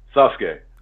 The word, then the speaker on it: Sasuke: What's the proper pronunciation of it? What do you call him? – Sasuke. – Okay, thank you. I'm not crazy.